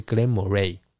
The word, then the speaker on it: Glenmorangie: OK，好，在市场上啊，有一个酒厂叫做 Glenmorangie，就是 Glenmorangie。我刚刚的那个发音是盖尔语